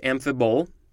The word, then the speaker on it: amphibole: Yeah, it's pronounced amphibole. First off, I got the pronunciation right.